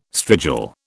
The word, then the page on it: strigil: British English